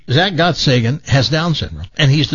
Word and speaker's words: Gottsagen: You pronounce it Gottsagen? – I think it's Gottsagen is what the directors pronounced it.